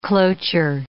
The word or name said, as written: cloture